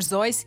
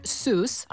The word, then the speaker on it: Seuss: Drago mi da ste izgovorili doktor Zojs, jer ja ga isto tako izgovaram, ali navodno nije tako, uvijek me ispravljaju. On jest nekog njemačkog podrijetla, ali Amerikanci ga izgovaraju doktor Seuss.